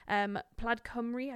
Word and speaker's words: Plaid Cymru: So there's some that do want the vote for 16 plus, and that is Green Party, Labour, Lib Dems, Plaid Cymru. I don't know if I said that properly. – Nailed it. Plaid Cymru.